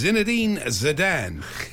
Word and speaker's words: Zinedine Zidane: It's, no one can pronounce his name on TalkSport, it's Zinedine Zidane.